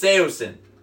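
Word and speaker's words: Saosin: Seosin was like the way to pronounce it. And then everybody in the band since called it Seosin. It's Seosin.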